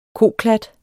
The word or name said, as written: koklat